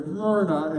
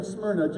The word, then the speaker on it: Smyrna: Now, my wife said you got to kind of say it like this, Smyrna, right? She's like, I don't know how else you're supposed to say it. She's like, you just go Smyrna, and you'll get it right every time.